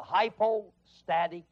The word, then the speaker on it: hypostatic: Now, in theology, that's called the hypostatic union. Hypostatic. You want me to spell that? Hypostatic is H-Y-P-O-S-T-A-T-I-C.